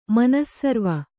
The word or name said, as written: ಮನಸ್ಸರ್ವ